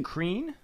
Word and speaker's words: Crean: And Crean? – I believe. Isn't that how you would pronounce it? – I suppose so. C-R-E-A-N, yeah.